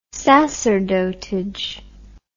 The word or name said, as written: sacerdotage